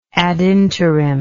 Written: ad interim

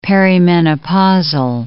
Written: perimenopausal

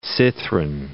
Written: cithren

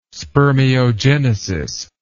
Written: spermiogenesis

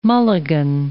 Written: mulligan

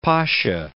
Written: Ali Pasa

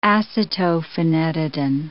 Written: acetophenetidin

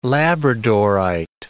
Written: labradorite

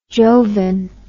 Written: jovan

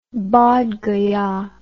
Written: Bodh Gaya

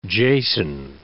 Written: Jason

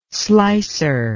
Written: slicer

